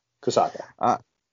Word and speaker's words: Kosaka: Kosaka.